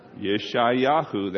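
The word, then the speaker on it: Yeshayahu (ישעיהו): Isaiah, pronounced in Hebrew correctly, is this. You ready? You're going to win. I want you to repeat after me. You ready? Yish. You ready? Yish, Yai, Yahu. Yeshayahu, that's Isaiah.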